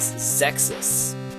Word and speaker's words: Xexyz: I found a commercial for it though, and it said the name of the game out loud. So I finally like learned how to say it out loud like a week ago. – And so The game is spelled X E X Y Z.